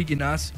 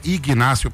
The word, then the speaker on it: Ignacio: José Ignacio Pessoa? Não, não, o G você não pronuncia, ele é mudo, ele, ó, eu ignorava.